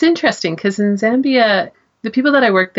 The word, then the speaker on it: Zambia: Now, you said Zambia, right? Did I say that right? – Yes, you did say it right. – I always called it Zambia.